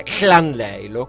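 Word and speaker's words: Llandeilo: A Capodanno dell'anno scorso ero, fortunatamente per me, in Galles, in un paese che si chiama doppia L Andeillo, la pronuncia è Clandeillo, quindi immaginatevi che cosa significa andare semplicemente pronunciano la K come Paolino Paperino. – Esatto, la doppia L diventa okay. K, quindi okay.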